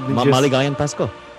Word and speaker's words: Maligayang Pasko: Maligayang Pasko.